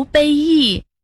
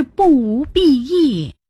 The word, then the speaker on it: 裨益: 我还以为那个字读作卑，我常说不无卑益，原来正确的是不无裨益。